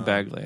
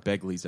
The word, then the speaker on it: Bagley: Are you going to pronounce his name Bagley or Begley? I hear a lot of Begleys out there. – Why would it be Begley from New York? – I know, but people say Begley.